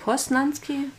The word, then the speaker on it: Poznanski: Posnanski? – Posnanski. – Wie schreibt man mit Post, Z? Mit Z. Posnanski.